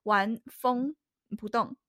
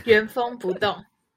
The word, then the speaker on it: 原封不动: 就我很想要把机智机智医生非常就是完那个那个字怎么念？完风不动，嗯，原封不动。